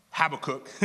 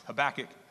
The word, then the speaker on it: Habakkuk: Often pronounced Habakkuk, as I pronounced it when I first ever saw it, is actually pronounced Habakkuk.